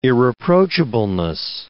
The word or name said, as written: irreproachableness